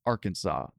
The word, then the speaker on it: Arkansas: Since the letter S at the end of French words is usually silent, we pronounce Arkansas as Arkansas.